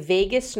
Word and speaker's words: vagus: Tip number five, and this is a big one, is doing vagus nerve activation. I know. Vagus is V-A-G-U-S, not like Las Vegas. Okay. It's spelled differently.